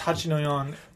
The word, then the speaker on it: Hachi no Yon: So that's Hachi no Yon. – Hachi no Yon. – Spell that. – Which is how you say 8-4 in Japanese. – Right. – H-A-C-H-I-N-O-Y-O-N.